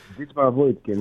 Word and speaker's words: Dietmar Woidke: Είχαν την κυβέρνηση διαρκώς 30 χρόνια, εξαιτίας και του τοπικού πρωθυπουργού, του Dietmar Woidke, όπως προφέρεται. – Dietmar Woidke, ναι.